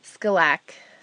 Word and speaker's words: Skilak: Bonnie Rose Ward is the author of Winds of Skilak,